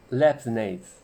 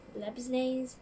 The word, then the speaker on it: Leibniz: Anyway, um, there's also like uh, that Leibniz, or I cannot pronounce his name. – Leibniz， Leibniz.